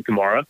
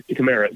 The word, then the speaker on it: Kamara: If, he, if he's in a situation where he cannot check it down to Alvin Kamara, or Kamara, excuse me, he, he was very clear about how he wants his name pronounced.